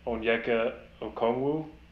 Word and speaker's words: Onyeka Okongwu: I'm going with, once again, apologies for the mispronunciation, Onyeka Okongwu.